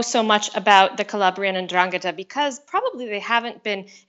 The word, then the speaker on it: 'Ndrangheta: It's 'Ndrangheta. – 'Ndrangheta.